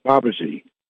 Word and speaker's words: Babaji: Babaji, might be pronounced Babaji. – Yes, yes. Right, that's correct.